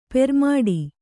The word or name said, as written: ಪೆರ್ಮಡಿ